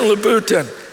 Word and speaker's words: Louboutin: Louboutin? Is that right? This is obviously not my wheelhouse here. Yeah, Christian Louboutin.